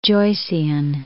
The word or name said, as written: Joycean